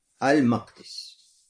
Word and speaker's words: Макдис: Два варианта, которые известны в арабском языке, как произносится Бейт-эль-Макдис. Первое – это с фатхой буквы мим, то есть Аль-Макдис. Хорошо? Бейт-аль-Макдис. Это первый вариант. Второй вариант – буква мим с ламмой и каф, и даль с шаддой.